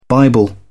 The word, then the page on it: bible: British English